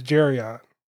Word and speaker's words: Jerion: So my name is Jerion. – Nice. – There you go. – Rhymes with Darion.